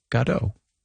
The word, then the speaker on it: Godot: But we did read that Beckett himself said Waiting for Godot.